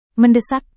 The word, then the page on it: mendesak: Indonesian